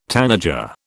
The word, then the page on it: tanager: British English